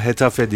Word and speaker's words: Getafe: Hetafe ya da Getafe, Getafe diye yazılıyor. Evet. Galiba Hetafe diye okunuyor.